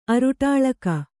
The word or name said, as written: ಅರುಟಾಳಕ